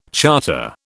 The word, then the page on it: charter: British English